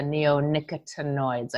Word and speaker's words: neonicotinoids: Because we've heard so much about the neonicotinoids. I think I pronounced it correctly. – Yeah, you did. You did well.